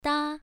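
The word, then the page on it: da: tone 1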